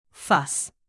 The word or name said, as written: fuss